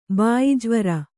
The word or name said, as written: ಭಯಜ್ವರ